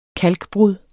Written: kalkbrud

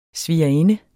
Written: svigerinde

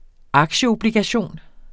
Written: aktieobligation

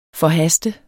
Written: forhaste

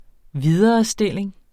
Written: viderestilling

